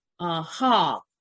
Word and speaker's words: chag: It also comes from a form of the word hog. And so hog is what you see on the screen here, C-H-A-G. That's how you pronounce it in Hebrew.